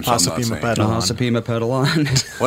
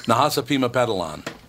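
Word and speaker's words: Nahasapeemapetilon: On Sunday night's episode, The Simpsons addressed criticism that its character Apu, I don't know how to say his name, Nahasapimapetilon. – What is it? Nahasapimapetilon? – Yes. Yep. – Nahasapimapetilon was a racist stereotype.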